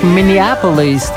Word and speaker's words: Minneapolis: Czyli jednak Minneapolis, tak jak śpiewa Boss. Inaczej wymawiałem, ale wiemy, o co chodzi.